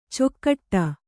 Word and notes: ಚೊಕ್ಕಟ್ಟ: cokkaṭṭa

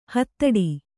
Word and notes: ಹಿದೌಠ: kannada

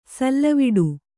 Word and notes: ಸಲ್ಲವಿಡು: sallaviḍu

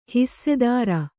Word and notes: ಹಿಸ್ಸೆದಾರ: hissedāra

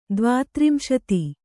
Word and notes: ದ್ವಾತ್ರಿಂಶತಿ: dvātrimśati

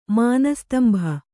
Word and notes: ಮಾನಸ್ತಂಭ: Kannada

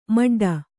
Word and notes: ಮಡ್ಡ: Kannada

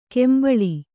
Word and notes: ಕೆಂಬಳಿ: kembaḷi

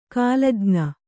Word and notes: ಕಾಲಜ್ಞ: kālaj'na

